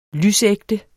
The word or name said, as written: lysægte